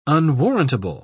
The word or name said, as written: unwarrantable